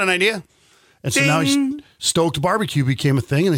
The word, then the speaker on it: StOAKed: That's StOAKed. That's what I said. Yeah. – Yeah, you said it right.